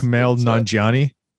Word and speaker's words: Kumail Nanjiani: I always say his last name wrong because it's Kumail Nanjiani.